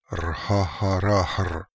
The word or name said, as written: Rhaharahr